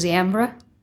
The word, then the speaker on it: Ziambra: Hello, and my last name is pronounced Ziambra.